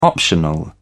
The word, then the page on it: optional: British English